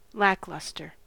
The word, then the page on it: lackluster: American English